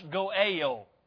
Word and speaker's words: goel: The word for redeemer right here is goal. It's usually pronounced goel.